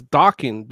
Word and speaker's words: Dokken: That's why I pronounced the kin as Dokken, because the band came first.